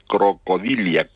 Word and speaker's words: crocodilia: Crocodilianos, crocodil, no estoy pronunciando mal, es que científicamente se dice crocodilia, crocodila.